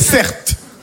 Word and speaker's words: Certe: Mon prénom, c'est Certe. Ça s'écrit C-E-R-T-E.